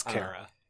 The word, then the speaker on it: Chara: Chara, how do you pronounce the character from Undertale? – It's Kara.